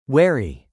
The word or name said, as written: wary